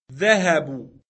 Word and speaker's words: ذهبوا: Now the phrase they went is ذهبوا. – Note that there is an aleph at the end of the word which is not pronounced.